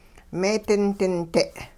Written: meedinding-de'

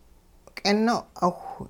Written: k'ino'awh

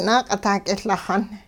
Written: naaq'itah k'iLaxun

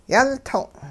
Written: yultong'

